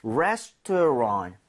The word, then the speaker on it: restaurant: Restaurant, restaurant. 여기 RANT를 랑. – 랑트도 아니고 랑도 아니라 살짝 런에 가깝습니다. 그러니까 restaurant, restaurant. T가 살짝 나긴 하지만 연음에선 거의 안 나거든요.